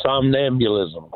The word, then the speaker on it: somnambulism: The somnambulism question.